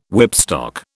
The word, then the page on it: whipstock: British English